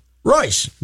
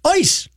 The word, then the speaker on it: Reuss: One of them, the river is pronounced Royce. – Right. – But when you do the German pronunciation, it's Ois. I showed it to Rookie. – Yeah. – Ois, Ois, Ois. – The R is not, the R is, for some reason, the R is silent.